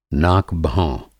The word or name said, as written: नाक-भौं